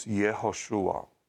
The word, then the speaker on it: Yehoshua: The Hebrew pronunciation of the name Joshua is Yehoshua.